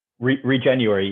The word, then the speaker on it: Regenuary: Am I saying that right? – Regenuary. You are. Yeah.